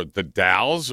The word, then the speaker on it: The Dalles: Dallas with an E. Is that how you pronounce that? D-A-L-L-E-S? – The Dells. – The Dells. – The Dells, Oregon. – Or the Dalles or the Dells? – No, it's called the Dalles. – The Dalles. Okay.